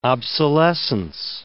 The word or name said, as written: obsolescence